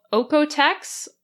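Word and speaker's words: OEKO-TEX: Another is called OEKOTEX, that's spelled O-E-K-O, and then Tex, like Tex-Mex.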